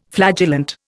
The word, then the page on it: flagellant: Indian